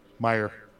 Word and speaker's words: Meijer: Representative Pete Peter Meijer Meijer Meijer, Do you know how to pronounce that? Meyer.